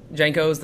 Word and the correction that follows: Janko's: the steakhouse? – Oh, Yonkos. – Yonkos. Yeah, okay.